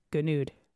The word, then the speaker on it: Gonoude: Andrew, how do you, is it Gonoude? Gonoude. – This is. Oh, yes, you pronounced it correctly.